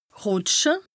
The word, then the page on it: rùtsche: Bas-Rhin; Strasbourg